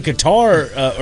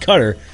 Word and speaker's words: Qatar: You know, when it was in Qatar, or it's pronounced Qatar, but back in 2022, he was all over the boards and all over everything.